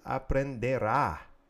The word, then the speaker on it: aprenderá: And that's él aprenderá. Accent on the last A in the future tense.